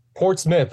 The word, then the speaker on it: Portsmouth: I think I'm pronouncing this right, Portsmouth, New Hampshire. Is that correct? – It's Portsmouth. I made that same mistake when I moved over here. – It says Mouth, but it's actually Smith.